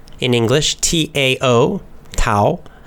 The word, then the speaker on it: Tao: And sometimes you'll see it spelled with a D, although less often you'll see it's D-A-O. But it's always pronounced duh with a D sound. So it's very confusing. It's spelled with a T, T-A-O, but then we say Tao.